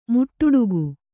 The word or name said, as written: ಮುಟ್ಟುಡುಗು